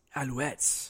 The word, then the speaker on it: Alouettes: So do you think this whole experience, this experiment with Johnny Manziel with the Aleuts is working? – I believe it's pronounced Alouettes. – Alouettes, whatever.